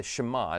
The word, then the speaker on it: Shema: Now, one of the things that's also true is that this prayer that he prays, that he recites, is called the Shema. It's how it's pronounced in Hebrew.